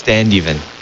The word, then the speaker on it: Standeven: Nicholas Standeven.